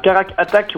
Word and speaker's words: Carac Attack: c'est le projet Caratac. Tu peux nous en dire deux mots Ouais, il t'a oublié une syllabe. Carac Attack, ouais, c'est une.